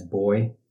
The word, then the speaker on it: BOI: Sometimes you'll hear that pronounced as boy.